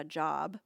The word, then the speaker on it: Job: Well, don't feel bad, because job is spelled J-O-B, right? But this book is referring to a man's name, and we pronounce it Job. Job. So it's about a man, and his name is Job.